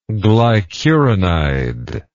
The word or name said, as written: glycuronide